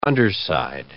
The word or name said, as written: underside